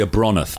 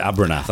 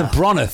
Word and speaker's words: Abronath: So it's called the Abronath, but everyone else called it Abernath. I'm like, it's Abronath, for God's sake. Say it right.